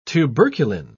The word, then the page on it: tuberculin: British English